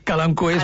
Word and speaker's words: Kalanchoe: Bueno, jo li dic Kalanchoe, tu has dit Kalanchoe, suposo que en llatí Kalanchoe és més correcte. – Sí, en llatí no es pronuncia l'H, tot i que no es pronuncia bé el llatí, però aquesta me l'he après.